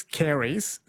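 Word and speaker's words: caries: The word is Carey's, C-A-I. – No. Can I pronounce it for you? Yes, it is Carey's.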